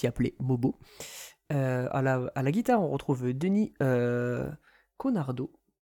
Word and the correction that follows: Cournardo: Voilà. Cournardo, pardon, voilà, je me trompe, j'ai écorché son nom, voilà.